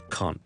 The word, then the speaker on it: can't: 例如我哋读 can't 嘅时候咧，英式英文啦，我哋 R vowel can't，you can't be serious，that can't be true，OK，and、 okay?